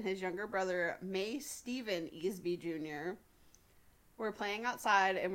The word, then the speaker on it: Easby: Easby? – Yeah. – E-S-B-Y? – E-A-S-B-Y. Like, easy with a B.